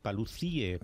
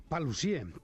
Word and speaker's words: Paluzie: Con acento en la E. – Correcto. – Paluzie.